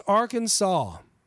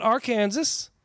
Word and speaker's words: Arkansas: Arkansas must be pronounced Arkansas, not Arkansas, even though that's the way it's spelled.